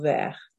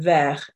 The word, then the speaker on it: vert: So as you can see, les ends in S, haricot ends in TS, and vert also ends in TS. And the S on les, the TS on haricot and vert are completely silent.